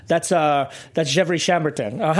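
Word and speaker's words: Gevrey-Chambertin: He's like, oh, that's Gevrey Chambertin.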